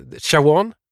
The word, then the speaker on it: Siobhan: Jag har försökt slå upp hur man ska uttala Siobhan. – Ja. Eh, Shawan? – Mm. Något ditåt kan vi väl säga.